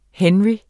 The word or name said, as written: henry